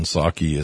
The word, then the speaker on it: Psaki: The other part of this is Psaki, who never Again, the P is silent in Psaki as well as the P in president for Biden.